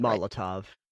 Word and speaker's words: Molotov: You find 7 cookies, flight dust, Malhov cocktail. I'm not pronouncing that right. I know I'm not pronouncing that Molotov. – Right. – Molotov cocktail. – Molotov cocktail. Thank you.